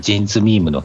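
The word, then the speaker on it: JINS MEME: ミトリーさんがジンズミームの件言ってくれてますね。 – MEME と書いてジンズミームと読みますってことです。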